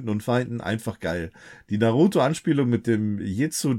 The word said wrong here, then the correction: Jutsu: des äh, Zusches Nee, Jutsu, habe ich das jetzt richtig ausgesprochen?